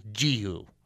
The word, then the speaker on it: Jehu: It's actually Jehu. I just like saying Jehu because I can remember it easier. Okay, the correct pronunciation is Jehu.